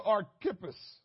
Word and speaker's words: Archippus: It says in Colossians chapter 4, verse 17, and say to Archippus, that's how you pronounce it. I got to be telling y'all, you know, I got to say this is how you pronounce it because y'all be thinking I'm saying crazy stuff.